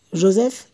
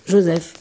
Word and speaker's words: Joseph: Et à Istanbul, vous retrouvez votre deuxième fils, Joseph. – Oui. – Joseph. Arrête de prononcer en polonais, ça va pas du tout, là. – Joseph. Joseph.